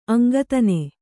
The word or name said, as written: ಅಂಗತ್ತನೆ